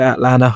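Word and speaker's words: Atlanna: That is how you say it, Atlanta. I'm not trying to do an American accent.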